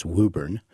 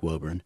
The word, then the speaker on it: Woburn: And the pronunciation in Massachusetts of the town is Woburn, It looks like Woburn.